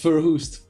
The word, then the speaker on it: Verhoest: Yeah, it's Verhoest.